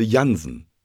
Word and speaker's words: Jansen: Ich heiße Jansen. Das schreibt man J-A-N-S-E-N.